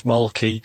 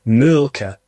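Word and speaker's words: Mölkky: We use Malky. Most of the people we play against use Malky, but the Finns have a very unusual pronunciation, which, please forgive me, I'll try it. It's Mulka. So I think that's, something a bit more like that. But to us, it's Malky.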